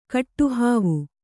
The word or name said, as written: ಕೆಟ್ಟಹಾವು